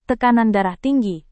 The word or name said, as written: tekanan darah tinggi